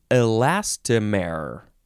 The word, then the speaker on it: elastomer: Next is elastomer. I think that's how you would say it. Elastomer. E-L-A-S-T-O-M-E-R.